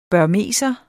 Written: burmeser

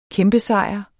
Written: kæmpesejr

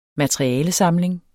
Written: materialesamling

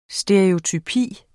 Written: stereotypi

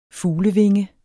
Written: fuglevinge

